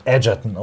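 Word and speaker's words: Egerton: Er wird Edgerton ausgesprochen, okay. hat er selbst gesagt.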